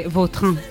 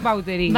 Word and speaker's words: Vautrin: Vautrin. Bueno. Pero aquí decimos Vauterín. – Claro, sí, sí. ¿No? – Pues, es que si me pregunta exactamente cómo se pronuncia, pues así. Claro, pero sí. Yo ya soy Vauterín. – Ya. – Está españolizado total.